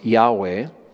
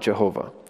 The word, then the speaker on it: Yahweh: But most, I would say, the vast majority of modern Jewish or Christian scholars believe that it should have always more properly been pronounced Yahweh, not Jehovah.